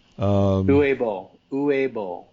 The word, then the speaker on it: Uwe Boll: The Uwe Boll story or Boll story? – Yeah. Uwe Boll is how I've heard it pronounced.